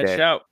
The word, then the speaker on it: Schaut: Schaut? That's right. – Yep, okay. Ned Schaut.